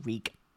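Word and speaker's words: Rhug: Reeg as in R-E-E. – It's R-H-U-G, but you pronounce it Reeg.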